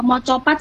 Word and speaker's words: Macapat: Mocopat sih kak bacanya. – Oh bacanya mocopat ya. Oh agak ke Jawa-Jawa gitu.